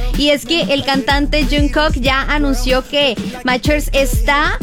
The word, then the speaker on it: Jungkook: Jungkook.